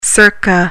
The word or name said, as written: circa